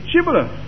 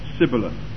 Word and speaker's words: Shibboleth: And if he said, "Nay," then they said unto him, "Say, Shibboleth." And he said, "Sibboleth," for he could not frame to pronounce it right.